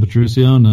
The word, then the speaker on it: Petruccione: It's just my name, Adam Petrucione.